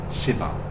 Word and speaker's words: Shiva: Said Shiva. The Sh just joins with the V because it's a short E. It's not long.